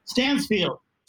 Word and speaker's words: Stansfield: Stansfield.